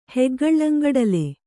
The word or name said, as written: ಹೆಗ್ಗಳ್ಳಂಗಡಲೆ